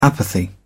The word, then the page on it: apathy: British English